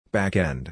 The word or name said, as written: Backend